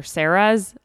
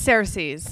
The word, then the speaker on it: Ceres: I think, like, from Game of Thrones, or Sarah's. I don't know. One of the two. – It's Ceres. – Okay. Well, yeah. C-E-R-E-S. – Yeah, it's Ceres. – Really? It's spelled Sarah's.